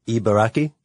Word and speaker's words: Ibaraki: Ibaraki. – Ibaraki.